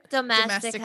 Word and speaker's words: Domestika: And I saw recently you were doing a course with is it Domenica? Yeah. Is that how you say it? – Domestika. – Domestika. Oh, Domestika. Yeah. Oh gosh, so close.